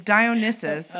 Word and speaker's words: Dionysus: No, it's Dionysus. Okay. I keep mispronouncing.